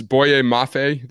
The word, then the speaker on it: Boye Mafe: 30 to Chiefs, Boye Mafe. That's how you pronounce it? – Yep. That's exactly right.